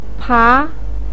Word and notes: pa: 2

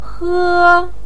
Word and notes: he: 1

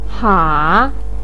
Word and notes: ha: 3

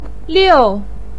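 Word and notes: liu: tone 4

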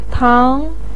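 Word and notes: tang: tone 2

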